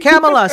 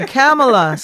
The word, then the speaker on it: Kamilah: Kamilah, sorry, I'm saying it wrong. Kamilah, smack me. I think I heard her say it too, Kamilah, so I'm sorry for not pronouncing your name right.